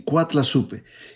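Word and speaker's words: Coatlaxopeuh: Se cree que Nuestra Señora usó el término azteca náhuatl de cuatlazupe, el cual es, es pronunciado así, cuatlazupe, y suena extremadamente parecido a la palabra en español Guadalupe.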